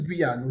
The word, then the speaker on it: Guyane: French is Guyane. We say Guyane.